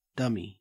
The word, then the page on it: dummy: Australian English